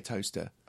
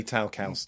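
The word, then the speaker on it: Towcester: And it's spelled T-O-W. – Okay. – C-E-S-T-L, but you say it Toaster. – Ah. – But lovely. – The amateurs say Towcaster.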